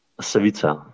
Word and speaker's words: Savica: Maybe Savica or Savica waterfall? Like, how to pronounce it? – Savica. – Savica, sorry. Oh my gosh.